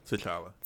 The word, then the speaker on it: T'Challa: But it's always been T'Challa. I hope I'm saying that right. – Yeah, T'Challa.